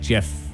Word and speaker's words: GIF: Yeah, I'm a GIF guy, not a GIF. I like GIF, like the peanut butter. It's a soft G.